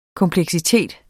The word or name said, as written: kompleksitet